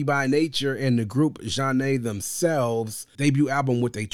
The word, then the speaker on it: Zhané: We're talking about none other than the debut studio album of R&B group Jah-Nay,